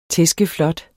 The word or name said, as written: tæskeflot